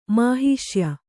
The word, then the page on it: ಮಹಾಶಯ: mahāśaya